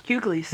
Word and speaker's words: Hughleys: The Hughleys? – Hughleys. – Is that how you pronounce that? Yeah. Hughley? Okay.